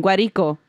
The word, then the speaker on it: Guarico: Of course I said it wrong. Guarico. – Oh, you like added. – I added an M and an I.